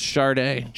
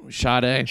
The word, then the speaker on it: Sade: Do you pronounce S-A-D-A? S-A-D-E? Is that always pronounced Sade? – Sade. – Like the singer? Sade? – Sade.